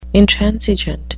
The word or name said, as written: intransigent